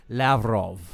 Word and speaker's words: Lavrov: Lavrinov. – Ugh. Astronaut. – Excuse me. I mispronounced the name of Russian. His name, Sergei Lavrov.